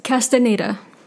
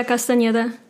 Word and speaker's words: Castaneda: It's Castaneda. – Okay. – Marvella Castaneda. In Spanish, it's Marbella Castaneda.